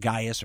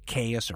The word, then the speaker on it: Gaius: You can call this person I'm about to talk about Gaius or Gaius, Caius or Caius. Those are all different pronunciations from different periods and different approaches.